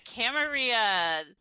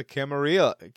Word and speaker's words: Camarilla: So we're going to be talking about the Camarilla. It's going to be potato, potato here for this episode. – Well, I personally prefer the Spanish pronunciation with the, you know, double L equaling the Y sound there.